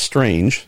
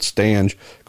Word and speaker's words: Stange: It's not Strange, as written, but Stange, going part-time with Oriel Servia next year.